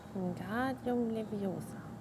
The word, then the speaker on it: Wingardium Leviosa: Wingardium Leviosa. – Genau, die Betonung äh, auf dem Gar und auf dem O. Ich glaube, du musst das eher nicht rollen.